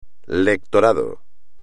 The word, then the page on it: lectorado: espagnol